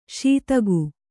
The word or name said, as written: ಸದೆಗ